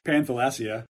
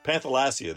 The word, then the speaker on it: Panthalassa: Panthalassia. – Panthalassia, there we go.